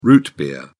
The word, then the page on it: root beer: British English